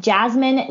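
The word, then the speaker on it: Jasmin: So I am Jasmine Nemec on Instagram.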